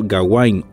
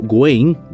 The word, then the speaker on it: Gawain: Es una de las tantas obras sobre el rey Arturo, aunque no precisamente de él, sino sobre su sobrino Gawain o Gwain, dependiendo quién lo pronuncia, como lo digan,